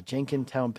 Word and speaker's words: Jenkintown: Let's go to Betty Jane in Jenkintown, Pennsylvania. Betty, did I pronounce the name of your town right? – Yes, you did.